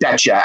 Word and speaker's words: Bettger: but he pronounces it betcha, as in you betcha.